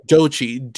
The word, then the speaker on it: Doechii: Is that spelled with a J? Like J-O-J-I or no? – No, Dochi. D-O-E-C-H-I-I.